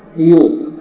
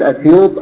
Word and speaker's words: أسيوط: الإمام السيوطي لعلكم يعني لمحتم من خلال تسميته أنه من نصر ومن السيوط، وتنطق سيوط أيضا بغير همز في أولها كما حققه يقول في معجم البلدان، يعني كلاهما سواء إن قلت أسيوط أو قلت سيوط.